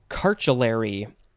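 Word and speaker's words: chartulary: Our next word is cartulary. But it's, you know, it's pronounced car, but there's a CH at the beginning.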